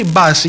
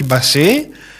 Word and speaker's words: Μπάση: Η ΠΑΕΠ Ανατολικός τον προφέρει Μπάση και όχι Μπασί. Κάποια πρώτα δημοσιεύματα μιλούσαν και τον προφέραν ως Μπασί. Τελικά είναι Μπάση.